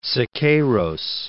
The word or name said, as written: Siqueiros